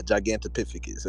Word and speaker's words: Gigantopithecus: Yeah, Gigantopithecus. That's how you say it.